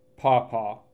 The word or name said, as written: Paw Paw